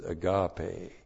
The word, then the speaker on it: agape: And after going through 1 John and studying 1 John, you are clued in when the Apostle John uses agape. A-G-A-P, long E.